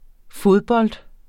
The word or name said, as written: fodbold